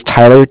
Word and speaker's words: Tyler: Okay, so my name is Tyler, T-Y-L-E-R, and I'm calling from Cardiff. It's spelled C-A-R-D-I-F-F, as in Frank Frank.